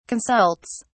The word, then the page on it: consults: British English